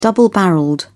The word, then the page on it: double-barrelled: British English